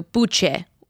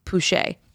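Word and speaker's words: Puche: It was supposed to be Puig, and then it was too hard to pronounce, so he changed it to Pouchet. So, but we've now, we just say Pouchet.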